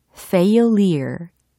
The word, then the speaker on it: failure: 발음이 되게 중요해요. 어, failer이 아니라 failure. 이와 같이 연습을 해 두셔야 되거든요. 철자 먼저 알려드리면 F-A-I-L-U-R-E 라는 철자입니다.